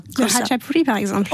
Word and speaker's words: khachapuri: Khachapuri. – Ah, voilà. Ouais. Et mais on dit Khachapuri.